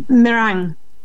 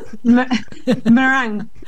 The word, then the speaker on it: meringue: Meringue. – Otra vez, por favor. – Meringue. Parecido como en español, pero ahí le sacamos la I.